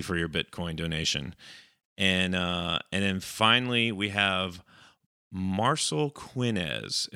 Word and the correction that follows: Marcelo: But it's like there's an O in there. Marcel O. – There is an O, yeah. Marcelo.